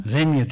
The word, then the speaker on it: Xenia: Ama İngilizler nedense o X'i başta olduğu zaman X olarak telaffuz etmiyorlar. Z olarak telaffuz ediyorlar. – Xenia. – Xenia diyorlar, evet.